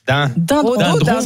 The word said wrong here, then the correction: rhododendron: C'est piégeant. – C'est dendron. Alors c'est rhododendron.